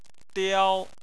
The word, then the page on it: diao: 1